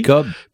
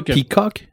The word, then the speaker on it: Pequod: Oui, c'est le, c'est le Je ne sais pas comment ça se prononce, P-E-Q-U-O-D. – PECOB. PECOB, ouais. – ouais. Alors, euh, bon, c'est, c'est pas Peacock? mais c'est PECOB avec un D, mais là, la prononciation.